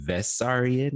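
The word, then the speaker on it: Vissarion: I've also seen Vessarion, which I guess is like maybe Beso. Just a different it's pronunciation just like the way from they a pronounce different B's and V's.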